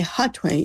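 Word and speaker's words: Hatuey: I say Hatway, even though H is silent in Spanish. It's H-A-T as in Tom, U-E-Y, the name of the great indigenous leader from the 1500s.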